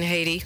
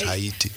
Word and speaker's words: Haiti: You mean Haiti? – Yeah, and Haiti. That's say, how it's pronounced, Haiti.